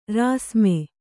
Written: ರಾಸ್ಮೆ

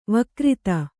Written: ವಕ್ರಿತ

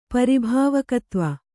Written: ಪರಿಭಾವಕತ್ವ

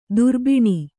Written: ದುರ್ಬಿಣಿ

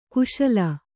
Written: ಖುಶಾಲ್

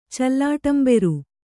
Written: ಚಲ್ಲಾಟಂಬೆರು

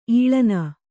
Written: ಈಳನ

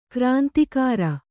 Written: ಗ್ರಂಥಕಾರ

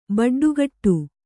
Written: ಬಡ್ಡಿಗುಡು

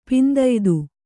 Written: ಪಿಂದಯ್ದು

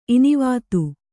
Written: ಇನಿವಾತು

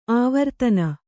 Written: ಆವರ್ತನಿ

